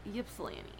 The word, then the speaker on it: Ypsilanti: Is it Ypsilanti or Ypsilanti? – Ypsilanti. Like, at least yippee?